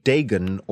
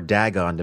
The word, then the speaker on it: Dagon: Yeah, they worship Dagon or Dagon, depending on how you want to pronounce it, and also Mother Hydra, and depending on who you ask, Cthulhu as well.